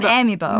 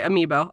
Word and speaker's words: amiibo: But I think it's Amiibo. It's not Amiibo. – No, I think it's Amiibo. – I think you're right, Amiibo.